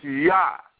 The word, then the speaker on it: Yah: It's not with a J like in the King James, Jah. No, it's not, because there's no J in the Hebrew alphabet. There's no J. So it's Yah. It's Yah.